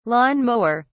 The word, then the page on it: lawn-mower: British English